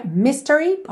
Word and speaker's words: mystery: Normalmente seria mystery, ok? Se você estivesse apenas dizendo essa palavra, a sílaba tônica é a primeira.